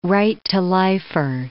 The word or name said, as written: right-to-lifer